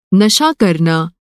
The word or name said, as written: नशा